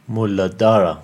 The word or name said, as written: Muladhara